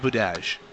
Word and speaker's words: Budaj: It's pronounced Budaj.